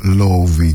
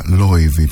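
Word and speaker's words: Laufey: Η Λόουφη λοιπόν, που στην πατρίδα της στην Ισλανδία την διαβάζουν ως Λόουβη και μάλιστα Λόιβη πιο σωστά,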